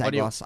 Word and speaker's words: Agwos: So Agwos. Am I saying that right, David? – You're the pronunciation person. – I am the pronunciation. – So it's your call. – You're right. It's Agwos. I was just like being considerate by throwing it to you, but no, it is Agwos. You're absolutely right.